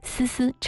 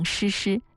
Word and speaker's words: 思思: 伍佰把思思唱成诗诗，但是制作依然是保留了这样明显的发音错误，认为它就是伍佰的特色。